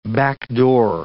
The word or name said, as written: backdoor